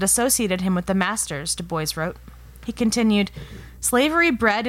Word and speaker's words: Du Bois: In his essay, Black Reconstruction in America, W.E.B. Du Bois is how he pronounced his name.